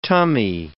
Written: tummy

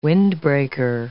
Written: Windbreaker

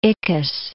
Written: Ickes

